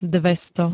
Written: dvesto

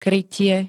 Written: krytie

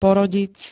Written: porodiť

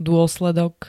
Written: dôsledok

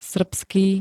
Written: srbský

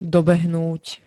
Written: dobehnúť

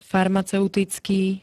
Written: farmaceuticky

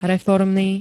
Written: reformny

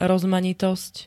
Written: rozmanitosť

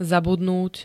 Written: zabudnúť

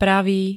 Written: pravý

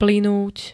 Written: plynúť

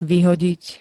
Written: vyhodiť

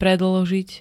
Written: predĺžiť

